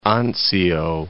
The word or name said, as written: Anzio